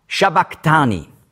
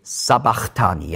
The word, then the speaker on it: sabachthani: In der deutschen Bibel steht Sabachthani, ja? Aber warum? Weil im Griechischen gibt es kein Sch-Laut. Dann schreiben wir das als S. Und das Chi hat man eben nicht ausgesprochen Ch, sondern K, K. So ein C hinten, K. Shabak, Shabaktani, das entspricht dem Kof im Aramäischen.